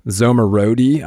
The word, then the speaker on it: Zomorodi: Thanks so much for hopping on here, Manoush Zomorodi.